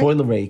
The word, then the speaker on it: Coi Leray: Coi Leray.